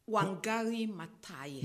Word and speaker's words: Wangari Maathai: Wangari Maathai.